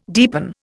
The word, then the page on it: deepen: Indian